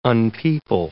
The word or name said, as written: unpeople